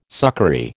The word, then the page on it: succory: American English